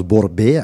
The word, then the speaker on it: Bourbia: de mon nom de famille qui se prononce Borbea euh, en, arabe hein, et qui est écrit orthographié Bourbia avec un A.